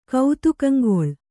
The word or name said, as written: ಕೌತುಕಂಗೊಳ್